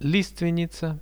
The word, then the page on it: listvennica: Russian